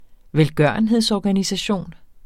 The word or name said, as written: velgørenhedsorganisation